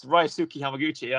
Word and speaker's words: Ryusuke Hamaguchi: Yeah, it's Ryusuke Hamaguchi.